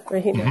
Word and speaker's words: Regina: So what happens with, you pronounce it differently than I would pronounce it, because I'm not fluent in Spanish. Regina? – Regina, mm-hmm. Yes.